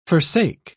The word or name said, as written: forsake